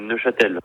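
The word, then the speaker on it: Neufchâtel: On dit Neuchâtel ou Neuchâtel? – C'est Neuchâtel. – Neuchâtel? – Oui, c'est bien ça. – Donc, on ne prononce pas le F? – Oui, exactement. J'ai toujours entendu Neuchâtel sans le F.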